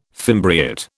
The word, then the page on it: fimbriate: British English